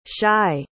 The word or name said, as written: shai